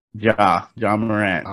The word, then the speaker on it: Ja Morant: But yeah, Ja Morant, that's how you say it? – Ja Morant.